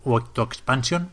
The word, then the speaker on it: Octo Expansion: Por ejemplo, está jugando a la octoexpansión de u, Octo Expansion, porque no lleva acento en castellano tampoco, de Splatoon 2.